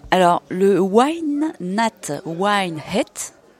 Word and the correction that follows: Wine Nat White Heat: Festival, est-ce que je le dis bien ? Non, pas du tout. – Wine Nat White Heat.